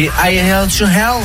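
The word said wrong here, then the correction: Highway to Hell: I Way to Hell, l'autoroute vers l'enfer.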